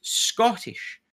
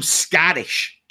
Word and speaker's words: Scottish: Why the hell do you Americans, that's right, all you Americans, pronounce Scottish with an A? And what I mean is, like, he said some Scottish guy. – Scottish. – And I'm like Scottish. – Scottish. – There is an O in the middle.